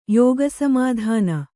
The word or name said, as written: ಯೋಗಸಮಾಧಾನ